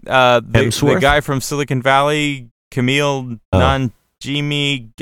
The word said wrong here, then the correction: Kumail: Kumail Nanjiani.